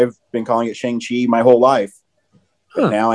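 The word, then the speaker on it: Shang-Chi: But anyways, he's in it and he talks about how everyone pronounces it Shang Chi, and that's not the correct way. Um, it's Shang Chi, mm-hmm.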